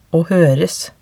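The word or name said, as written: å høres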